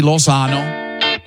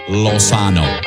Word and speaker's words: Lozano: L-O-Z on my last name. Lozano. I have to say it like that because I got an old school Mexican dad. He makes me.